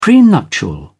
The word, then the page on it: prenuptial: British English